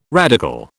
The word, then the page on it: radical: British English